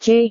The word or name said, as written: c